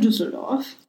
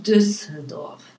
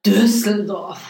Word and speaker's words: Düsseldorf: Allerdings ist es in Düsseldorf. Und es ist das ist falsch ausgesprochen. – Ich bin gerade gerne in Düsseldorf. In Düsseldorf. – Düsseldorf. – Mit einem Seufzer und Augendreher. – Düsseldorf.